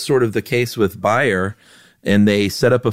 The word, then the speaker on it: Bayer: If you heard me say buyer and you're thinking, dude, it's Bayer aspirin. What are you, German? No, I'm not German, but that's right. How you pronounce it over there. It's Bayer. We pronounce it Bayer over here.